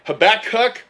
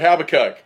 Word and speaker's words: Habakkuk: Is it Habakkuk or Habakkuk? – It's either one.